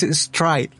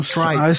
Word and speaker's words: Stride: Ah, no, perdón, lo, pronuncié mal, es Stride. Ah, Stride. Stride. – Ah, okay. Sí, dije Street.